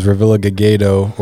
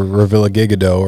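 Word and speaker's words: Revillagigedo: Referred to as Revilla typically because Revilla Gigado or Revilla Gigado are the two pronunciations of it.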